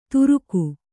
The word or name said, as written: ತುರುಗು